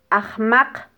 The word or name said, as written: ajmaq